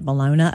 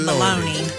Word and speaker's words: Bologna: She celebrated while vacationing in Bologna. I think they pronounce it Bologna over there, Italy. – Italy! – Oh, she's in Italia! Bologna! – We call it Bologna.